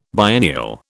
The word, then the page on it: biennial: British English